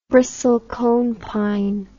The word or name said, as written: bristlecone pine